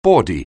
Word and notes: bawdy: anglais

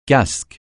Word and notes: casque: francais

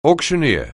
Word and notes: auctioneer: anglais

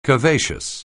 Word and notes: curvaceous: anglais

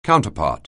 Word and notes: counterpart: anglais

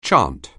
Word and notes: chant: anglais

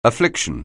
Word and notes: affliction: anglais

